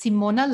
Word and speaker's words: Simona: So it's Simona, like Simon with an A.